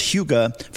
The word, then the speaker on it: hygge: It's spelled H-Y-G-G-E, pronounced HUGA from Denmark, and jumping on that movement because it just feels better.